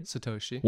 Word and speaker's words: Satoshi: One Satoshi, am I saying it right? – Satoshi.